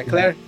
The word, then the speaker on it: Claire: Você tinha como jogar o jogo na, na, na pela visão do Leon e pela visão da Claire, se não me engano, ou eu falei errado? Ah, é Claire? Isso. Isso, é.